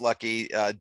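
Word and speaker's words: Lachy: It's L-A-C-H-Y is his first name, pronounced Lucky, D-O-L-E-Y, Dolly, and the Australian blues rock guy.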